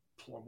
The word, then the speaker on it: Plum: Is it Plume or Plum? – Plum, I don't know. P-L-U-M. However the Pennsyltuckians pronounce it. – Plum, Plume, something like that. I don't know. It's a five-letter. Sorry. No, it's four. It's Plum, P-L-U-M. – Yeah. – It's four. Plum. Yeah, okay.